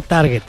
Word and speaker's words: target: No, pendejo, es target. En inglés se pronuncia target.